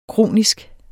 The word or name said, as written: kronisk